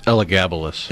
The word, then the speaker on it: Elagabalus: Elagabalus, is that the name of the emperor? Is that right? Elagabalus. – I think it's Elagabalus. – Elagabalus. Okay, well, I wasn't sure where to accent the syllables, but... – You put the wrong emphasis on the wrong syllable.